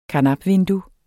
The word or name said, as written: karnapvindue